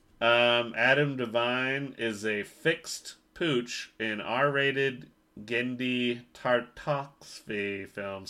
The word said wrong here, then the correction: Genndy Tartakovsky: Sorry for mispronouncing that. Tartakovsky. Genndy Tartakovsky.